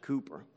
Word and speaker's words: Cowper: It's spelled C-O-W-P-E-R, but it's pronounced Cooper.